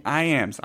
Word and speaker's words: Iams: They Call Me Iams. Iams is spelled just like the dog food. Just like that.